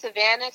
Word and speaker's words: Savannah: Well, you can find me everywhere at Savannah Cristina. So that's Savannah spelled like the city, S-A-V-A-N-N-A-H. And that's Cristina with no H, C-R-I-S-T-I-N-A.